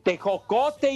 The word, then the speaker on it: tejocote: el ponche que trae el tecojote, el miembrillo, todo, la caña. tejocote y membrillo, güey. Pronuncia correcto.